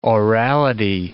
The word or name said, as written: orality